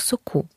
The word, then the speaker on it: соку: Кулінар розповідає про томати у власному соці і помиляється з формою місцевого відмінка. Помідори у власному соку. Адже наголос переходить на закінчення.